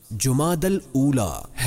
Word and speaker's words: جمادی الاولی: درست نام اور صحیح تلفظ جمادی الاولی ہے۔